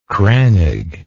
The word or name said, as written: crannog